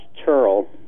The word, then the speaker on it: Turl: By the way, my last name is pronounced Turl. It rhymes with pearl, if that helps.